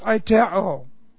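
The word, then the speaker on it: aiteo (αἰτέω): Here's aiteo. A-I-T-E, long O.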